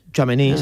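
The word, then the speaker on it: Tchouaméni: ¿Chuamení? – Chuamení, sí.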